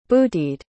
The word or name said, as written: bootied